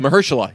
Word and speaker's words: Mahershala: We found out how to pronounce Mr. Ali's first name. – Yeah, I I've already forgot. – Uh, fuck. Mahershala? Uh, Mahershala, yes. Yeah, that's right.